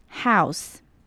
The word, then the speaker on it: house: 再来看到 house 这个字， house 这个字呢，我们要注意到它的 OU 的发音，在前几集的口说挑战里面都有提到 OU 呢有几种发音，有时候会是像 OW 的声音，就像这里的 house，可是像 could should 它就是短短的 OU 的音。 house house。